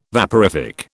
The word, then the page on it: vaporific: British English